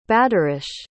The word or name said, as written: badarish